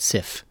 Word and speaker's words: SIF: I think the Facebook-linked NASA story you are referencing is measuring sun-induced chlorophyll fluorescence, or SIF, pronounced like Sith, but SIF.